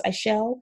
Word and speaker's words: Ixchel: Uh, I think it's Ixchel, pronounced Ishel.